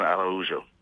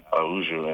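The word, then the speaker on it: Araujo: So a lot of Puerto Ricans will say I'm mispronouncing it, but there's Araújo in Spanish and Araújo in Portuguese.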